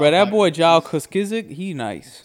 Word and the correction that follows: Juszczyk: His name's like Kyle Juszczyk.